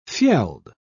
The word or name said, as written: fjeld